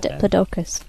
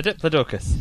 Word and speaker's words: Diplodocus: There's a picture of an elephant here compared to the size of a Diplodocus. Is that how you pronounce it? – Diplodocus. – A Diplodocus, yes.